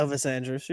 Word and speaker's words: Andrus: Yeah, Elvis Andrus, you're good.